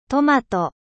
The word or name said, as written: トマト